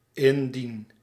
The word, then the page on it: om: /ɔm/